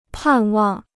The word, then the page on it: 盼望: pàn wàng